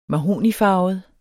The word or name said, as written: mahognifarvet